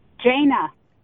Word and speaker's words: Jayna: Jaina. – Spell it. – J-A-Y-N-A.